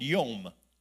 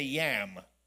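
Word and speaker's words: yam (ים): It's the Hebrew word yom. We say yam. Spelled the same way. YAM.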